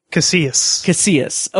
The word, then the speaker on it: Casillas: Casillas. – Okay, Manny Casillas. – Just think you say tortilla. – Mm-hmm. – So, Casillas, you don't pronounce Casillas. The L's.